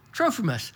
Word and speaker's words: Trophimus: I left Trophimus, how do you say that? Trophimus.